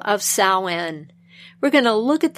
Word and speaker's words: Samhain: And the oldest is Samhain, and that is spelled S-A-M-H-A-I-N. There are various pronunciations, but this is the most common pronunciation.